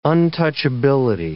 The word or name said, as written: untouchability